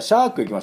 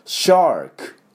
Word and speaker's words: シャーク: じゃあシャーク行きましょう。シャークはですね、 SH の音がポイントです。これシャークではないですね。 SH の音はシー、うるさいよ、シーっていう時の感じですから、シャーク、これぐらいシーと音を出してください。